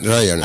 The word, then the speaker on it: Ryanair: ¿Cómo se pronuncia? ¿Spanair? – Spanair. – Spanair, Ryanair. – Ryanair. Ah, Ryanair, vale.